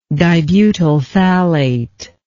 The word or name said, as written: dibutyl phthalate